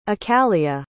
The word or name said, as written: accalia